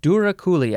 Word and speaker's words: douroucouli: This is douroucouli. Douroucouli. I think that is how you pronounce it. It is spelled D O U R O U C O U L I.